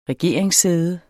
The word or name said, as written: regeringssæde